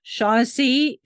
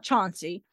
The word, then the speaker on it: Shaughnessy: Shaughnessy, it's not Chauncey, not even spelled the same way.